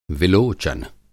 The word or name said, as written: विलोचन